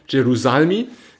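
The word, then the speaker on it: Jerusalmi: Ähm, wenn man jetzt mal dem Chef der äh, Borsa Italia glauben kann, das ist äh, Signor äh, Gerusalimi, Gerusalimi, habe ich richtig ausgesprochen, Gerusalmi, genau, der hatte ein längeres Interview in der FAZ, was da ganz aufschlussreich war, wo er eben auch gefragt wurde.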